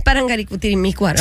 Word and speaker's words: Parangaricutirimícuaro: Pero el mío es Parangaricutirimícuaro. – Así que. – Por eso lo dije, el primero lo dije Parangutirimícuaro. – No, no, te falta una sílaba, pero dale.